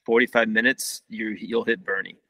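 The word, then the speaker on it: Boerne: We keep referring to Bernie, Texas, but Bernie actually looks like it's spelled Born. – Yeah. Everyone says Born. Yeah. – Everyone that doesn't know says Born, Texas. – It's Bernie. It's Bernie. Bernie, Texas.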